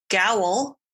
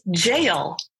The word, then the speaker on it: gaol: As I already knew, I had mispronounced gowl. – Oh, yeah. – Because that's how it was spelled. But it is actually pronounced as jail, which is something that my dumb American ass would not be able to know looking at it when you spell it G-A-O-L.